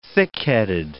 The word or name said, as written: thickheaded